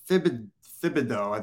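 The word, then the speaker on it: Thibodeaux: or Kayvon Thibodeau, I think his name is, from Oregon. I would love to have either or. – H is silent.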